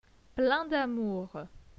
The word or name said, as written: plein d'amour